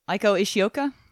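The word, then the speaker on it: Eiko Ishioka: Aiko Ishioka? – There you go, yes.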